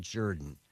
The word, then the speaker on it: Jordan: And it's spelled Jordan, but he pronounces it Jordan.